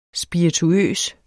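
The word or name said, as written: SPIRITUØST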